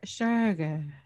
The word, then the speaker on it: Shergar: It's spelled S-H-E-R-G-A-R. – Sugar. – Yeah, sugar. There's no umlaut. – Okay, so it's like sugar with an accent.